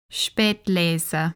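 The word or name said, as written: Spätlese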